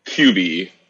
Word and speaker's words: Cuby: Cuby.